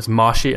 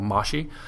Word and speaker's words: moshi: And this is called Mashi, and that's spelled MOSHI, so Mashi.